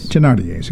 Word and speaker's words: Thénardier: Other directing credits include Thénardier's, am I saying that right? – Thénardier's, excuse me.